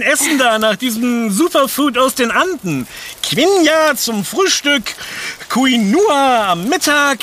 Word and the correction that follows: Quinoa: Also erstens ist es gar kein Getreide und zweitens heißt es Quinoa. – Quinoa.